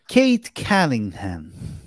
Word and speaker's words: Cade Cunningham: Kate Cunningham.